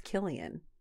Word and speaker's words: Cillian: Well, I said Cillian Murphy, but Sean, our sound guy, said it's pronounced Killian.